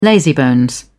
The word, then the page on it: lazybones: British English